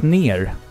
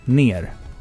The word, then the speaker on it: NieR: Ehm, jag har spelat ner. N-I-E-R. Ner. Ner. – Hur uttalar man det? Ner. Eh, säg ner.